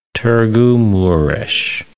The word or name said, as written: Targu-Mures